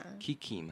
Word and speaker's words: キキ: 对，真的是科普，我也是找了一些资料才知道的。嗯、好，那回到我们电影本身，就是《魔女宅急便》这个琪琪呀，你知道日文怎么念吗？Kiki 吗？ – 对，然后它的猫咪叫做吉吉，对，吉吉。那那我们就来介绍一下哈，魔女叫做 Majo，然后宅急便叫做 Takyubin，它的黑猫叫做 Kuroneko，就是黑猫，名字叫做吉吉。嗯嗯。好，那琪琪叫做 Kiki。